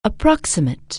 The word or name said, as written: approximate